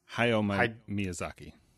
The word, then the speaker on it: Hayao Miyazaki: Okay, so this thing on our 2020 challenge is, if I say the name wrong, correct me, Hideo Miyazaki's? – Hayao Miyazaki. – Hayao Miyazaki's. – I mean, I'm still pronouncing it wrong, but H-A-Y-A-O.